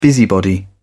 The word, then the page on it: busybody: British English